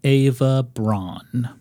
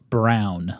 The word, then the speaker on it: Braun: Her last name is pronounced Brown, like the color. – Oh. – Not Braun, like the brawny, sexy paper towel guy.